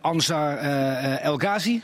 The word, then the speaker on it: Anwar El Ghazi: Alleen, ik zie iedereen in Engeland en Anwar El Ghazi, zeg ik het goed? – Anwar El Ghazi.